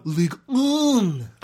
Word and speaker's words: Ligue 1: But Ligue 1. – Ligue 1. That's how I believe it's pronounced. – I think that's perfect.